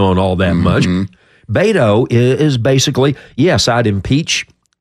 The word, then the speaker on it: Beto: I like to call him Beto because it's Beto. And besides, Beto rhymes with mosquito, and I like to call him that.